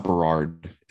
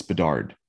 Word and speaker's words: Bedard: It's not Berard. It's Bedard. Okay.